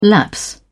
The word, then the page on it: lapse: British English